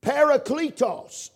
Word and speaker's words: parakletos: Parakletos is the name, is the way you pronounce it.